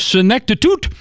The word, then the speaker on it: senectud: So the word of the day is Senectud. – Senectud. – Senectud. I got it over here. I got it over here. – It's actually pronounced Senectut.